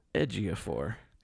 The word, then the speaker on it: Ejiofor: Ejiofor.